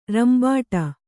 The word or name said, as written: ರಂಬಾಟ